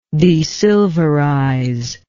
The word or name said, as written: desilverize